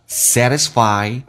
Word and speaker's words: satisfy: I was always satisfied Sadness T 发 D 这个发音，来体验一下 Satisfy I 饱满一点，底部发音气流阻塞一下。